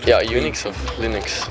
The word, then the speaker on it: Linux: As Linux.